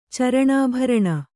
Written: ಚರಣಾಭರಣ

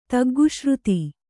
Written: ತಗ್ಗುಶ್ರುತಿ

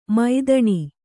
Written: ಮೈದಣಿ

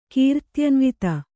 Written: ಕೀರ್ತ್ಯನ್ವಿತೆ

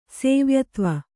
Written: ಸೇವ್ಯತ್ವ

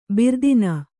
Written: ಭೀರುತನ

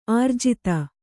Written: ಆರ್ಜಿತ

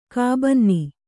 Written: ಕಾಬನ್ನಿ

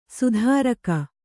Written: ಸಾತಿರೇಕ

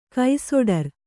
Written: ಕೈಸೊಡರು

